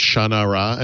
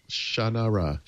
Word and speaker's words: Shannara: So I met the author of that book and he told me that it was pronounced Shannara. And I said, no, it's not. And I'm not pronouncing it that way. It's like, I pronounce Sha-na-na. – Yep, Sha-na-ra. – Sha-na-ra.